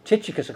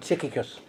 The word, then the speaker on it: Tychicus: Okay, so now then, there's a big dispute about how you pronounce this fella's name. Tychicus or Tychicus. Tychicus, I quite like it.